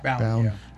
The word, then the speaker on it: Bown: How do you say her last name? B-O-W-N. Bound? – Bound. Yeah.